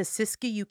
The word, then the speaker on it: Siskiyou: But it just so happens to be over the border into Siskiyou County, California. Did I say that right? – Yes.